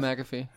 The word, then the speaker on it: McAfee: Who, McAfee? Wait, is that his name? Am I saying that right? – Pat McAfee.